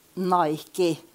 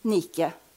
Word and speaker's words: Nike: Men dette er iallfall Hvordan vil dere uttale navnet på det merket der? Nike ikke sant? – på amerikansk. Nike-logoen. Eh, vi skulle, hvis vi skulle si det ordentlig, skulle vi si Nike.